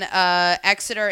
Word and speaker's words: Exeter: So this place, Beer Cellar Exeter, which they're a bar in Exeter, England. I'm sure I'm pronouncing that wrong. – No, it is. That's Exeter.